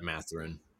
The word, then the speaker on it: Mathurin: How do you say his last name? Mathurin? Is that? – Mathurin. Yeah. I was calling him Mathurin all throughout the draft process, and I'd heard that before.